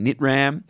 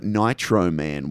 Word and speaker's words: Nitram: People would know him more recently for directing Nitram, or I think someone called it Nitro Man. Why? That's the wrong pronunciation.